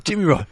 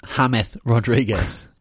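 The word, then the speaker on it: James Rodríguez: It's Ham Rod. You got to say it right. What a goal from Hamed Rodriguez.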